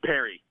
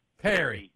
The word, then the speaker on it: Perry: Oh, and just like I'm particular about how y'all pronounce my name, my boy's name is Lincoln Perry. – Perry. – That's what I thought. Like, my John called him Pear.